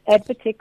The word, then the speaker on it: AdvTech: Did I uh, pronounce that correctly? It's AdvTech schools, right? Yeah, AdvTech schools.